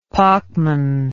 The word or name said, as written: Pachmann